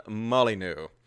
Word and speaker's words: Molyneux: But anyway, I'll try harder to pronounce his name, Stefan Molyneux.